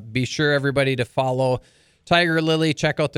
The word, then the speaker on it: Tigirlily: And Tigirlily is spelled with all I's because it's Tigirlily, Tigirlily.